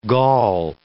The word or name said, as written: Gaul